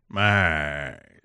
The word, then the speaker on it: Mize: we caught some play by play of him doing a Tigers spring training game, and Buck Martinez turned the last name Mize into a multi syllable name. – Mize.